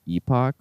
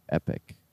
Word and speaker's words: epoch: Is this pronounced epoch or epic? – I think both are acceptable. Is that right, Steve? – I think so. – It's kind of like a tomato-tomato situation.